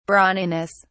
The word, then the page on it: brawniness: British English